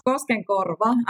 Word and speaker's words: Koskenkorva: And if we now sort of hone in on Koskenkorva, did I say that kind of okay? Koskenkorva. – Yes, Koskenkorva. I know it's not easy to pronounce, but yeah, that's the real name of the place.